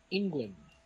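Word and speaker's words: Englund: Eh, la misma como tal fue dirigida y escrita por Wes Craven, este, descansa protagonizada por Robert England. ¿Lo dije bien? Sí.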